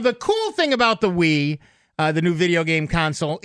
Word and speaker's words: Wii: As you know, Nintendo has joined has joined the fray when it comes to console gaming, and they have introduced the Wii, W-I-I, pronounced Wii.